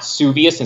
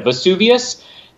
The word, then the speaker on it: Vesuvius: That was legitimately a mistake she made, where she mispronounced it Mount Vesuvius instead of Vesuvius.